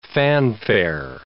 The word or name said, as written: fanfare